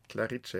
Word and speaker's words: Clarice: Und diesmal habe ich herausgesucht, das London Symphonieorchester spielt jetzt vom Mozart Clarice cara mia sposa. Habe ich es richtig ausgesprochen, Thomas? Stimmt das so? – Perfekt. – Also Clarice ist ein Name, oder? – Clarice, ja.